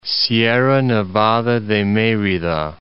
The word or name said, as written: Sierra Nevada de Merida